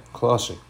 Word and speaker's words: Colossae: And if you read this letter that he wrote to the Colossians, um, I don't know, I'm not sure if I'm going to pronounce this right. Colossae? Colossae? Yeah, Colossae. So Paul writes this letter to them,